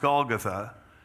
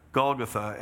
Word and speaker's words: Golgotha: In Hebrew, it's pronounced Golgotha. And there they nailed him to the cross. By the way, in Hebrew, it's pronounced Golgotha.